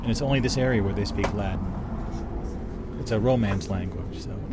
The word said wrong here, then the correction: Ladin: it's not Latin, it's Ladin. – Ladin, L-A-D-I-N, yeah.